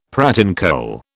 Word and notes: pratincole: British English

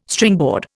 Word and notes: Stringboard: Indian

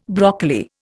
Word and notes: broccoli: Indian

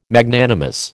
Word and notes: Magnanimous: American English

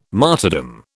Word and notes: martyrdom: British English

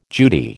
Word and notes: Judy: American English

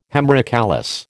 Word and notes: hemerocallis: American English

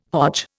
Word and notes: Hodge: Indian

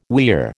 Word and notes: weir: American English

